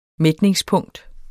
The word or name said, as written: mætningspunkt